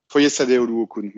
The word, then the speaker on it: Foyesade Oluokun: j'avais jamais prononcé son prénom, hein, Foyesad euh, Oluokun, qui est, euh, qui est un formidable. – Foyesade Oluokun, merci, euh, Elliot.